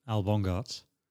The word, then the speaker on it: Al Bongartz: Am I saying it right? Your second name? – Close enough. Yeah? Yes, you are. Yeah. – How would you say it? – Al Bongartz.